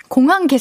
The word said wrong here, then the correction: 공학용: 계산기 말고 공학 공학용 뭐 공학용 계산기요 네.